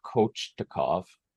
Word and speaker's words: Kochetkov: K-O-C-H-E-T-K-O. – Peter Kochetkov.